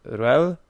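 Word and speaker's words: Ruehl: Co prawda żaden z tych dwóch aktorów nie, nie otrzymał tej statuetki, ale Mercedes e, Ruel, on tak, tak się jej nazwisko wymawia,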